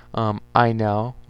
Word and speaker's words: Ynel: Inel, which is the Y-N-E-L, Inel stories as well.